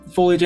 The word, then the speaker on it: foliage: You guys make your way down through the foliage, and it's still a little while. – Through the what, DM? – Foliage. – He's been saying it right. – Yeah, that was good. Foliage. I was just checking.